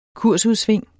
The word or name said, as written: kursudsving